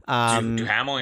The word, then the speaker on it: Duhamel: How do you say his name? Duhamel? – Duhamel, yeah.